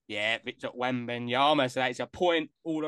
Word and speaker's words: Victor Wembanyama: Victor Wembanyama. – Oh, it's a good pronunciation as well. H, what have you gone for, my friend? – Victor Wembanyama.